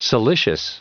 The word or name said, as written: siliceous